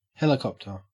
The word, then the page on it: helicopter: Australian English